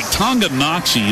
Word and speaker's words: Tonganoxie: All right, Tonganoxie is spelled T-O-N-G-A-N-O-X-I-E. – Just the way it sounds.